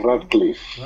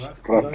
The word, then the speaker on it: Radcliffe: Y bueno, pues ya saben que Daniel Radcliffe, ¿cómo se pronuncia? – Radcliffe. ¿No? – Radcliffe, Radcliffe.